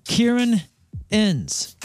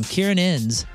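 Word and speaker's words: Ciarán Hinds: Kieran Ens. It's spelled C-I-A-R-A-N-H-I-N-D-S. I looked it up like seven times. How do you pronounce this guy's name? Kieran Inns.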